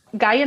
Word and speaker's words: Gaea: Gaia Novo is essentially two acronyms put together. So Gaia, the way it's spelled is G-A-E-A, It's the Greek way to spell creator, goddess.